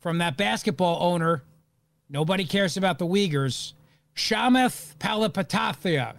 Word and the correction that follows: Chamath: I'm probably not saying that right, You're definitely not saying that right. – Shamath Palihapitiya. How do you say it? – It's Chamath is how you say it.